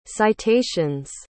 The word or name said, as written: citations